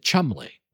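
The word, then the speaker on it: Cholmondeley: It's a Chalmondele is the word, but they pronounce it Chumley.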